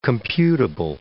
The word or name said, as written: computable